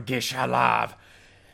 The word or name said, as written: Gish-Halav